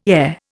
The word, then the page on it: ere: Indian